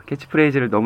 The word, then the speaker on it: 캐치프레이즈: 저는 한 단체의 이 캐치프레이즈 어, 캐치프레이저를 아유 발음 너무 안 좋네요. 캐치프레이즈를 너무 좋아했었거든요.